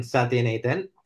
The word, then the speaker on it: Sathianathan: My name is Brian Sathianathan.